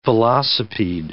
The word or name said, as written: velocipede